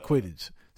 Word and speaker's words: Quidditch: Playing basketball and croquet and fucking squidditch, whatever the hell it's called. Like, did I not say it right? Quidditch? That's it. Quidditch.